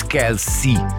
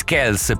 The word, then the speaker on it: Kelce: Kelce. Che poi lui è Travis Kelce, però leggevo che la famiglia ha accettato di farsi chiamare Kelce perché i media pronunciano Kelce, e quindi basti, dai, chiamatemi come volete.